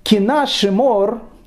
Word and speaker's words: Кина Шимор: Ну, «Кина Шимор» э, так оно на итальянском произносится, шимор, кина шимор.